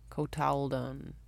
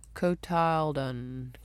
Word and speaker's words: cotyledon: Cotyledon.